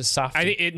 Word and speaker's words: Safdie: First one, The film was inspired by the Safdie brothers' father's, maybe is it Safdie or is it Safdie? – Now that you say Safdie, that probably is it. I don't know how I put the long A in there.